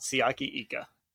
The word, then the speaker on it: Siaki Ika: they get Siaki Ika. Is, is that how you say his name? The Baylor defensive tackle? – Yeah, you yeah, you it? nailed it. Siaki Ika.